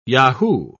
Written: yahoo

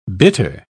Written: bitter

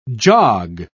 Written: jog

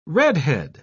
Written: redhead

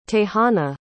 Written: tejana

